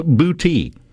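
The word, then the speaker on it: Boutte: It's B-O-U-T-T-E. It's actually pronounced booty. To the point to where when my wife moved to Mississippi, she thought Bude was pronounced beauty. Or booty. Honest mistake to make because that's, you know, that's what she was used to. But it's pronounced booty.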